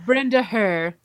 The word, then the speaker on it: Brenda Herr: Was it Brenda Herr? – Brenda Herr.